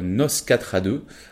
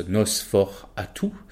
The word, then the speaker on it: Nosferatu: Nous avons Nos 4 à 2. Alors je le dis volontairement à la française, puisque c'est écrit comme ça. mais en réalité, ça se prononce Nos fort à tout.